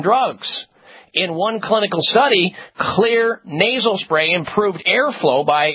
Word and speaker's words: Xlear: clear nasal spray with xylitol. Clear is spelled with an X. X is for xylitol. X-L-E-A-R.